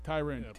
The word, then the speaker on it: Tyron: It's Tyron. If you look at the pronunciation guide, according to the NFL, is Tyrin. – Pronunciation guru. – -RIN. I'm just telling you what it says. Tyrin. Go look it up.